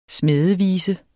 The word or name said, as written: smædevise